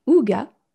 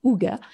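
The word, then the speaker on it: hygge: alors, il y a une prononciation, c'est Ouga. Donc, c'est le Ig en français prononcé à la française, mais en, le terme du coup du hig bien prononcé à la nordique, c'est Ouga.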